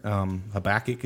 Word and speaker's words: Habakkuk: And yes, for those in the South, we pronounce it completely different. Habakkuk is usually how it's pronounced from anybody I know from this side of the woods.